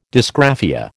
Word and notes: dysgraphia: American English

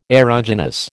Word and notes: aeruginous: American English

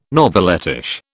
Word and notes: novelettish: British English

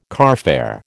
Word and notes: carfare: American English

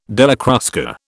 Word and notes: Della Crusca: British English

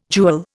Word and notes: jewel: Indian

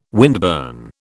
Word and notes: windburn: British English

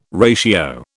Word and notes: ratio: British English

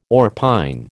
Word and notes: orpine: American English

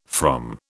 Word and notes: from: British English

